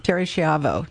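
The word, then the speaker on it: Terri Schiavo: Is the proper pronunciation, is it Terry Schiavo? – Mm-hmm. Terry Schiavo, yes.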